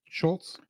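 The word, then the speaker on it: Schulz: Schultz.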